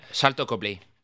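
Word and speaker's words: Sharlto Copley: Eh, Salto Copley o Sarito Copley, no estoy muy seguro cómo se dice. Salto Copley.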